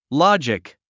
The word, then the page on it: logic: lɑ’dʒik; ロジック